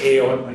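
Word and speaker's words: ale (אֵל): It's really, you pronounce ale. You might want to think you pronounce it L, but it's usually pronounced ale.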